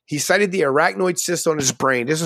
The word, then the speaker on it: arachnoid: He cited the arachnoid cyst on his brain. Did I say that right? Arachnoid? – I think you got that right, yeah.